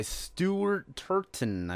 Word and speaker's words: Stuart Turton: The 7.5 Deaths of Evelyn Hardcastle by Stuart Turton, I believe is how you say it.